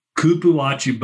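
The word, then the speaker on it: cupuaçu: What about, is it pronounced cupuachu butter? – Kupuachi butter. Yes, I researched the Amazon women.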